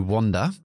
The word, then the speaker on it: wander: To wander, que parece una O, Wanda, pero se escribe con A, y es a ah, Bagad.